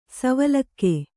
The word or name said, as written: ಸವಲಕ್ಕ